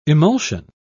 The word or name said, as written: emulsion